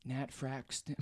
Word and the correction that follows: Nat Faxon: Nat Faxon and Jim Rash.